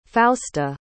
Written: fausta

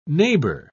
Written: neighbor

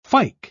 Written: fyke

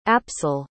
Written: apsel

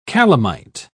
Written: calamite

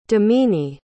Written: damini

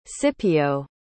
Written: scipio

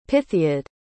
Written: pythiad

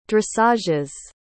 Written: dressages

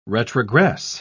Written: retrogress

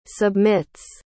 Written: submits

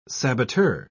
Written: saboteur

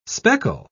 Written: speckle

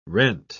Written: rent

